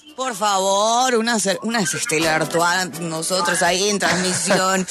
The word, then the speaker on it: Stella Artois: Stella Artois. – Mm. Stella Artois.